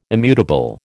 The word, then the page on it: immutable: American English